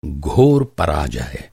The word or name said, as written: पराजय